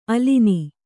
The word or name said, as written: ಆಲಾನ